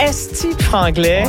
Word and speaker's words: Esti franglais: Esti franglais. Ouais. Est-ce que je le dis bien? – Oui, très bien.